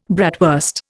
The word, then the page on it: bratwurst: Indian